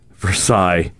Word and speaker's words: Versailles: Versailles, not Versailles, what? – Versailles. – But it's spelled like that. – It's pronounced Versailles. It's French.